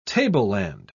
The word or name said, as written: tableland